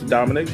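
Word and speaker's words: Dominick: And as always, if you wanna get exclusive items, use my code name in all caps, Dominic. That's D-O-M-I-N-I-C-K. Again, that's D-O-M-I-N-I-C-K, and get 10% off.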